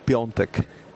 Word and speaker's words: Piątek: Mi novia es polaca y Piatek, y no sé cómo lo habéis dicho el nombre, se pronuncia Krzysztof Piątek. – Piątek. Sí, sí, lo que dijimos, que este se llamaba Piątek, pero como nosotros leemos tal cual, pues decíamos Piątek.